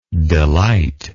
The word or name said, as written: delightless